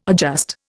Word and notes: adjust: Indian

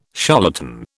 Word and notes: charlatan: British English

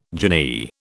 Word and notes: jinnee: British English

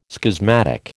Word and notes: schismatic: American English

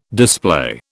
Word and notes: display: British English

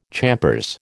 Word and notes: champers: American English

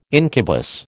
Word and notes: incubus: American English

